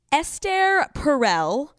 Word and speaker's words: Esther Perel: Esther Perel. That's how you pronounce her name.